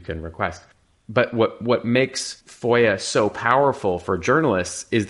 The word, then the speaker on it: FOIA: FOIA is the pronunciation of the acronym for the Freedom of Information Act,